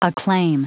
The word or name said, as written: acclaim